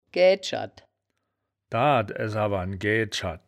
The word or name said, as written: gaeaed-scherd